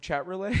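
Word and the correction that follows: Chatroulette: Did you say chat roulette? – Relay?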